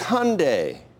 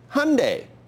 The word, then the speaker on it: Hyundai: It rhymes with Sunday, right? Hyundai. Is that good for you? Will that work for you? It's Hyundai. Sunday, Hyundai. Good.